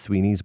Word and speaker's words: Sweny's: When you get to Dublin, there's a chemist shop in the middle of town called Sweeney's, or sometimes pronounced Sweeney's, but it's spelled S-W-E-N-Y-S.